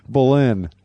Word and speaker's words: Boleyn: Boleyn. I had the emphasis on the wrong syllable.